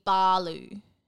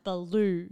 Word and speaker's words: Baloo: And yeah, Baloo is meant to be Balu. – Oh, so not – so Balu, not Baloo.